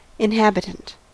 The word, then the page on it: inhabitant: American English